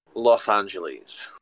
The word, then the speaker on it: Los Angeles: Some say Los Angeles and some say Los Angeles. I'm really angered by that. Which one is it? – Los Angeles.